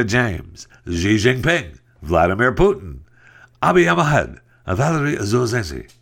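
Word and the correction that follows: Zaluzhnyi: Valerie Zaluzhny. Zaluzhny.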